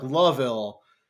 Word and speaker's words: Louisville: He's from Louisville, which is interesting because I don't think I know anyone from Louisville. Like, and it's not Louisville. It's more like Loville. But I think it's more like one syllable.